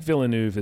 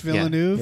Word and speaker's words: Villeneuve: That is it, Villeneuve? I'm terrible at pronouncing French, and I always have to practice if I've done some research for a podcast and I didn't. – I think Villeneuve is correct.